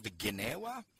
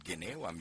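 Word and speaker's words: Gnawa: that something called Ginewa. – Yes. – Am I pronouncing that correctly? – Yes, you're pronouncing it correctly.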